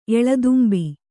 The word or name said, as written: ಎಳದುಂಬಿ